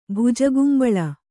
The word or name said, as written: ಭುಜಗುಂಬಳ